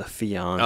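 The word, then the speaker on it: fiancé: Well, you know, I've spent lots of time with the Fiants. And talking wedding talk. – Fire ants. You got an infestation or? – Well, to pronounce it the fancy French way, the fiance.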